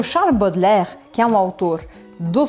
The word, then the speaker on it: Baudelaire: Esse nome, Baudelaire, parece que tem um monte de letra, mas você não tem que pronunciar a metade delas. Então fica assim o, o som mesmo. Baudelaire. Eu já ouvi muita gente falando Baudelaire. É Baudelaire, Baudelaire, Baudelaire.